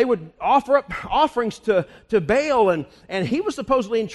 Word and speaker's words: Baal: No, what he was saying was, you know, they worshiped Baal, or Baal is the way it's pronounced.